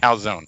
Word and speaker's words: calzone: You call it a calzone. The last E is silent. Now, I mean, technically, I think if you were really Italian, I think you'd say calzone.